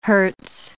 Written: hertz

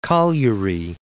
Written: Cagliari

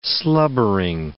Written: slubbering

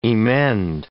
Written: emend

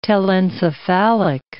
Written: telencephalic

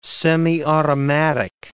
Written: semiautomatic

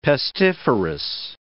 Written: pestiferous

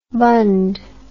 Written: bundh

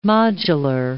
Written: modular

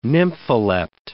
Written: nympholept

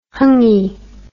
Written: hangi

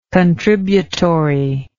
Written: contributory

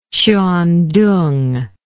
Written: Hsuan Tung

